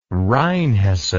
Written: rheinhessen

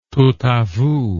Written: tout a vous

